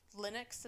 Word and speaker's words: Linux: And then he created a partition that has, like, is Linux.